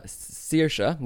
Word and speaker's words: Saoirse: So S-I-O-B-H-A-N is pronounced Siobhan. Or Saoirse, which is another Irish name, like Saoirse Ronan, the film star. That's spelled S-A-O-I-R-S-E and so forth.